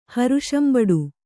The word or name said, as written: ಹರುಷಂಬಡು